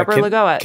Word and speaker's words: Lagoa: But then there are others like Lagoa. I don't know if I'm pronouncing that right. – Yeah, Lagoa.